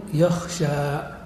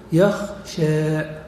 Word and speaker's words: يخشى: مثال ذلك يخشى، فالألف التي في يخشى تنطقها جمهور العرب بالألف الخالصة وقبلها فتحة خالصة فيقولون يخشى. ومن يميل، وهي لغة فصيحة، يميل الألف نحو الياء، وهذا يستلزم أن تميل الفتحة التي قبلها نحو الكسرة فتقول يخشى يخشى.